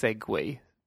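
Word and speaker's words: segue: Jag, jag, har ju fått lära mig i veckan här att, att det heter ju inte segway. Nej. Eller det, det, det uttalas segway, ungefär som, som den här eh, grejen som typ äldre rika män åker på.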